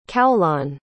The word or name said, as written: Caolan